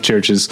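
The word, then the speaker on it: Chvrches: That's Churches with a V instead of a U, but it's pronounced Churches.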